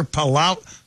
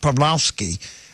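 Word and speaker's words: Pawłowski: And that's Arthur Palau. I think it's pronounced Pawlowski.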